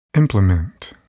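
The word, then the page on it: implement: verb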